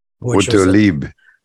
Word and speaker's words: Autolib: That was part of Autolib. Probably sounds better if you pronounce it in French. – Autolib.